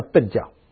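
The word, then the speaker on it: 苯教: 他们的民间宗教叫笨教，不是很笨的笨，但音是一样的，就叫笨教。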